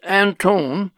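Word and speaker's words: Anthon: Martin Harris took the transcription from the plates to Columbia University and asked the famous scholar Professor Anton, sometimes pronounced Antone, to evaluate the transcription which had been copied and translated by Joseph Smith from the plates.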